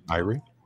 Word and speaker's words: Irie: Am I saying that right? – Irie. – Yeah. Irie. Is it Irie that does Golden Goat? Yeah. – He's making it sound like Erie, Indiana.